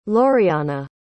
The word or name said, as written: loriana